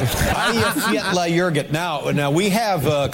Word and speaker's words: Eyjafjallajökull: Because the name of the volcano is so difficult to pronounce, it's pronounced, here's the pronunciation, Eyjafjallajökull.